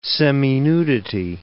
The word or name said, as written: seminudity